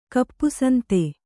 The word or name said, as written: ಕಪ್ಪುಸಂತೆ